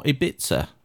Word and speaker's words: Ibiza: And we're going to Ibiza. Or Ibiza, as Kim Sassabone, the lead singer of the Venga Boys, says. An unusual pronunciation in English.